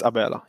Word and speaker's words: Abela: It's Abela.